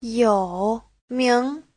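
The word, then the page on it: youming: you3ming2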